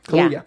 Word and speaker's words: Kaluuya: Is that how I say his name? Kaluuya? – Kaluuya.